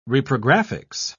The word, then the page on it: reprographics: British English